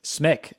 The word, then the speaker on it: Smick: Smick, S-M-I-C-K. – Just like it sounds. Like it's gonna say, it's simple, man. It's simple. – It's just going to say Smick. It rhymes with stick.